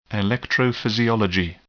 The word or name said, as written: electrophysiology